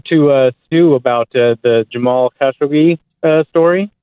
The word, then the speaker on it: Khashoggi: It's like that, Khashoggi. – It's Khashoggi? – Yeah, there's an emphasis on like the first syllable. – So it's not Khashoggi, it's Khashoggi.